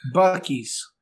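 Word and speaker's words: Buc-ee's: Oh, it's spelled B-U-C-E-E. – Bucky's.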